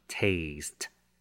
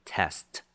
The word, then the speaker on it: taste: taste 是动词，表示是品尝，要特别小心它的发音，念成 taste 是长音，千万不要念成 test 测验。